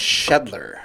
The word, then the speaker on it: Shedler: Shedler. – Shedler. Oh, that's pretty easy. – Just like it looks, right? – Dan Shedler.